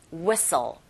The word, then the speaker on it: whistle: Whistle. 이렇게 발음하시되 T 사운드 역시 whistle이 아니라 whistle.